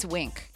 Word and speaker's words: Winc: and it's Wink. I love Wink. So it's spelled W-I-N-C, and Wink is simple.